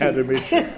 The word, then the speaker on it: Academician: Academician.